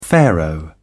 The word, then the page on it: pharaoh: British English